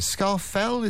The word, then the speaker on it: Scafell: How do you pronounce it? Scafell, is that right? – Yes, yeah.